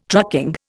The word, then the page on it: trucking: Indian